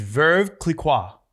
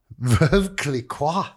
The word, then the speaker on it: Veuve Clicquot: How do you say this? Clicquot? – I think it's Veuve Clicquot. – Veuve Clicquot. – Yes.